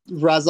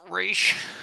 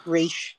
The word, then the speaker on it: Ra's: It's the Guardians in it, a bunch of different Arrow characters, Ra's al Ghul's in it a couple episodes. It's interesting. I recommend it. – Actually, it's pronounced Raish. – Raish.